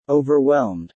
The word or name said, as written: Overwhelmed